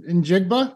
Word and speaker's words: Njigba: Jackson Smith Njigba.